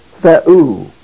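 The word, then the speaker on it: Theou (θεοῦ): The first syllable is the. The second syllable is oo. But the accent is placed over the upsilon. Theou.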